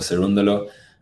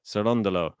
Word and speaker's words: Cerundolo: Let's check to see how to say his name properly. – Hi, I am Francisco Cerundolo, and you are listening to the Game to Lab podcast. There you go. Cerundolo. Okay. uh, as long as we, we've always got that just to confirm how to say his name correctly.